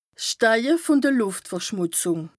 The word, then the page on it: 's Steige vùn de (Lùft)verschmùtzùng: Bas-Rhin; Strasbourg